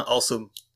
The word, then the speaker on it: Anseaume: Yeah, you say it right. You know that in the US, after a few moments with people, usually they call me Benjamin Anseaume.